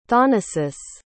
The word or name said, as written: thanasis